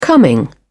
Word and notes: coming: British English

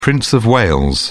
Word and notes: prince of wales: British English

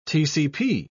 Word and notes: tcp: British English

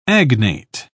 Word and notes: agnate: British English

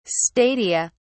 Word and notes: stadia: British English

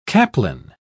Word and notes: caplin: British English

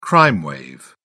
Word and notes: crime wave: British English

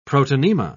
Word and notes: protonema: British English

